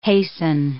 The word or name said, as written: hasten